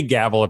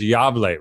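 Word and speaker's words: Gävle: Well, I know that you've already told me that I can say gavel if I want, but the way you pronounce it is Yavle, right? – Yeah, that's right. Yavle.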